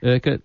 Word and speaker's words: Urquhart: Felicity Urquhart rhymes with circuit.